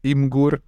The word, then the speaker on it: Imgur: Imgur.